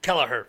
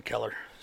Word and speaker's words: Kelleher: Just like you know, Kelleher. Sorry, I always pronounce his name wrong. Kelleher. No, he's pronounced him Keller.